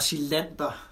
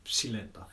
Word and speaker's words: Psilander: Og, og Silander, det er jo det var sådan, det udtales, han hedder jo PSI. Pss, ja. Silander.